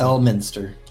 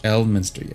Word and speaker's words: Elminster: how do you pronounce it? – Elminster. – Elminster, yes.